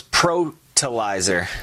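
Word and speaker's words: Protilizer: The product's called Protolizer. – Spell it's it for me, please. – It's a P-R-O. – Okay. – T-I-L-I-Z-E-R. – Okay, fertilizer, better pro. – So it's Protolizer.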